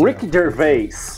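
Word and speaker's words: Ricky Gervais: Rick Gervais.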